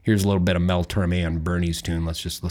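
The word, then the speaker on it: Tormé: You had mentioned when we were discussing it that Mel Torms... – Okay, so I said his last name wrong. Shoot me. – I'm sorry. I just had to bring that up. Mel Torme has a song called Bernie's Tune.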